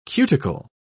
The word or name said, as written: cuticle